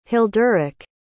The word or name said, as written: hilderich